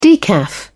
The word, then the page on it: decaf: British English